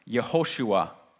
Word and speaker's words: Yehoshua: That's why in Hebrew, like I told you, it's pronounced Yehoshua. You can kind of hear Joshua there, right?